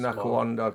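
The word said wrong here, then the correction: Nwankwo: Canu. – Nawanku.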